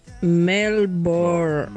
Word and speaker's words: Melbourne: Por favor, pronunciar bien. Melbourne. Es Melbourne. Sin la E. Como Melbourne. – Correcto. – Melbourne. – Es como Ramona Sherbourne.